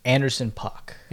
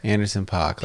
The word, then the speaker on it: Anderson .Paak: Anderson .Paak. – Anderson .Paak. Like Tupac? – P-A-A-K. – Oh, okay. – Yeah. – Is he P-A-A-K? – Yeah.